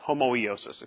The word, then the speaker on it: homoiosis: The other Greek word, so we have, so we have icon, E-I-K-O-N. The other Greek word is homeosis. Homeosis. H-O-M-O-I-O S-I-S, homoeosis, excuse me, I missed a syllable in there, homoeosis.